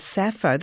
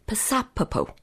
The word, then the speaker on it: Sappho: Well, let's talk about Sappho, but start with her name itself, because as we say her, Sappho, that's actually a mispronunciation, isn't it? – It is. It's very strange. If you speak to a native Greek speaker, they pop the P, which I hope I'm not doing weird and wonderful things to the microphone here, but she should be Psapopo.